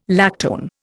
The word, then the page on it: lactone: Indian